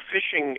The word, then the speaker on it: phishing: And phishing is a term for emails, basically, that come to you, and they're phishing, I don't know why it's P-H. They spell it P-H-I-S-H-I-N-G, but it's kind of like phishing, where they're phishing for a mark for somebody to take advantage of.